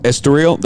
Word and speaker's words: Estoril: We want to go to Portugal so bad, go Marquez, uh, simply because of that that track in uh, Estori- uh, Estoril. Estoril, that's yeah. how you say it.